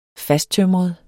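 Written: fasttømret

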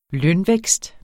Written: lønvækst

